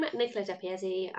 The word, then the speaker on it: Nicola Depiazzi: So, I'm Nicola Depiazzi.